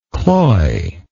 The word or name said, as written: cloyedness